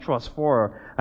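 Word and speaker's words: trosphora (προσφορά): And in the original language, offering, the word for that is trosphora. It's spelled like phosphorus, just without the U-S. It has an A on the end, but it's pronounced trosphora.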